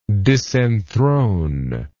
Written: disenthrone

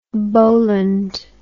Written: Boland

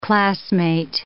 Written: classmate

